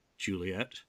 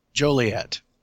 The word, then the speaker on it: Joliet: And he does tell the story of how he was on tour in Illinois, which of course has a city called Juliet. I don't know. Is that how you pronounce it? I'm not a Midwestern guy, so. – Joliet.